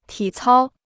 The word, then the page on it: 体操: tǐ cāo